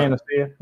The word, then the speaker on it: Panacea: Panacea? – Yeah. Panacea, yeah.